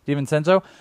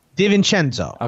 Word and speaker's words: DiVincenzo: At 29, it has the Nets taking Dante DiVincenzo. Is that how you say his name? – That sounds right to me. – DiVincenzo. – DiVincenzo.